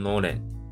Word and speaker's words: のれん: 那这个屋号就念作 Nolan，所以就把这个 Nolan Wake 拿过来用，就称呼这种创业的形态叫做 Nolan Wake Seido。